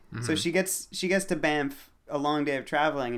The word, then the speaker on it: Banff: Definitely, I assumed based on how it's spelled Banff or Bannf. Neither of those is particularly... – I believe it's Banff. Yeah. I assume that it would be pronounced Banff, but it's Banff.